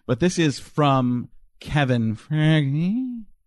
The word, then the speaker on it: Feige: That's Feige.